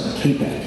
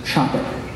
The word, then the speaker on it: Čapek: Reverend Norbert Čapek, looks like Capek, you'll see it look like Capek, it's pronounced Čapek.